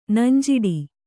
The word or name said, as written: ನಂಜಿಡು